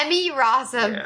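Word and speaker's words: Emmy Rossum: You have said her name wrong at every turn. – Have I? What is it? – Amy Rossum.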